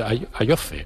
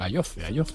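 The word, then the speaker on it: Ayoze: Entonces lo oyes en los partidos y dicen Ayoce. – Sí. – Queda un poco raro. – Queda, pero porque no estamos acostumbrados, realmente bueno. Ellos son los que pronuncian el correcto fonéticamente. – Tengo que discrepar. – No, de correcto nada, el correcto es el nuestro.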